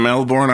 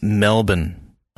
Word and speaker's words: Melbourne: I think it's Melbourne. I think they say like Melbourne. I think Melbourne? – It's a silent R, I think.